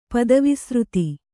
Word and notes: ಪದವಿಸೃತಿ: kannada

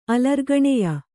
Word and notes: ಅಲರ್ಗಣೆಯ: kannada

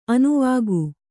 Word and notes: ಅನುವಾಕ: anuvāka

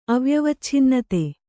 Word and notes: ಅವ್ಯವಚ್ಛಿನ್ನತೆ: avyavacchinnate

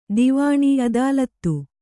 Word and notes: ಅದಾಲತು: adālatu

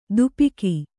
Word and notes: ದುಪಿಕಿ: dupiki

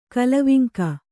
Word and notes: ಕಲವಿಂಕ: kalaviŋka

